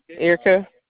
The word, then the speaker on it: Erica: Erica. – Can you spell that for me? – E-R-I-C-A.